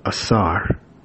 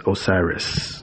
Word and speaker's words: Asar: He records the verdict of Asar, who many of you also know his name as incorrectly pronounced as Osiris.